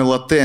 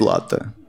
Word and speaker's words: латте: Я говорю, ладно, давайте мой латте. Она такая, правильно говорить латте.